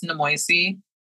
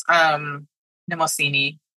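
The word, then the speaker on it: Mnemosyne: So in my head, it's Nemoisi, which is wrong. It's Nemosini.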